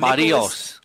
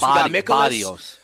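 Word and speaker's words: Berríos: You got guys like Jose, I'm going to say it right, Scott, Berrios. We got Barrios. – Barrios. – Barrios. – Yeah, like the Barrio, okay? Like El Barrio.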